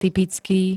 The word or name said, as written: typický